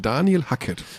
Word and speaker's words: Daniel Hackett: Daniel Hackett. Also auch nicht Daniel dann? – Nee, Daniel. – Und Daniel, auch nicht Danielo? – Also wir nennen ihn jetzt Daniel Hackett.